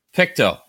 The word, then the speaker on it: Pictou: Pictou, like as gross as it sounds.